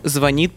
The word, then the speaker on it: звонит: Феномен звонит по средам, приняв договор по годам.